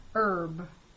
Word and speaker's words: herb: H is silent before vowels at the beginning of a few common words. Remember to use an instead of a before these words. Herb.